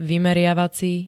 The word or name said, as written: vymeriavací